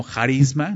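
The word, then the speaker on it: jarisma (χάρισμα): En griego esta palabra es se traduce como carisma o jarisma, ¿no? Con J se escribe.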